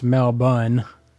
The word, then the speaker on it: Melbourne: Cheers, Richie from Melbourne, pronounced Melbourne, Australia.